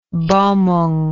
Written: balmung

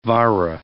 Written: vara

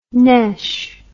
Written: nesh

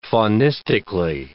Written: faunistically

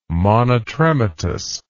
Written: monotrematous

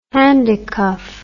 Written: handicuff

